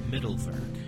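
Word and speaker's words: Mittelwerk: And by the way, it's pronounced Middlework.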